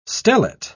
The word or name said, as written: stellate